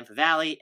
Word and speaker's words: Favale: I am at Dan Favale, F A V A L E.